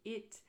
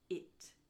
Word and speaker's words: it: Vous l'aurez sans doute entendu, mais it, du coup, se prononce bien avec un i court. Si je dis eat, par contre, c'est un i long et c'est le verbe manger to eat, E-A-T. Alors que là, c'est bien it.